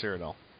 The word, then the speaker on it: Cyrodiil: Cyrodiil. Is that how it's pronounced? – Cyrodiil. – Cyrodiil, yeah. – Yes, Cyrodiil.